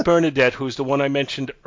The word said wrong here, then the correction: Benaderet: Actually, it's B Benaderet. Excuse me, I wasn't pronouncing it right.